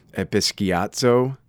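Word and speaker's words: episkiazo (ἐπισκιάζω): But I, I looked this, this word up, and in Greek it's uh, pronounced episkiazo. Uh, and so you break that down, epi means upon, and skiazo means to cast shade.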